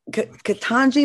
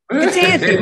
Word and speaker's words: Ketanji: Judge Ketanji, am I saying it right? Brown Jackson was moved to tears. – She's from Miami. Ketanji. – Ketanji, my bad.